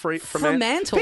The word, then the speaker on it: Fremantle: Fremantle.